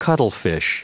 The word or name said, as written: cuttlefish